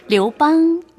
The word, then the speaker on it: 刘邦: 그리고 유방은? – 류방. – 류방. – 네. – 아, 아 귀여워요. – 어떻게? 류방. 본토 발음 다르죠? – 예.